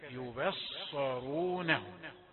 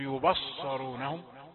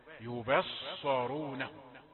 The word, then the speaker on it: يبصرونهم: النطق لكلمة يبصرونهم بترقيق الباء يباء، هكذا يبصرونهم، ولا تفخم الباء لأنها بجوار الصاد، لا تفعل هذا، لا تقل يبصرونهم، يباء يبصرونهم.